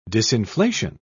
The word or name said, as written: disinflation